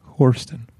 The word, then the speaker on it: Horston: Horston. – Horston. She taught it to us today. – It's Houston with an R.